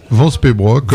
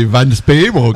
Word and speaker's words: Van Speybroek: Van Speybroek, à la française. À la flamande, c'est Van Speybroek.